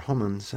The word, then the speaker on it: Homans: Talking to Jennifer Homans.